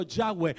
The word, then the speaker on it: Yahweh: El nombre del Eterno, el nombre de nuestro Dios Todopoderoso se escribe YHWH, y los judíos le colocaron la A y la E para poder pronunciarlo Yahweh.